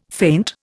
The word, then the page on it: faint: Indian